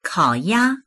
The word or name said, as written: kao3ya1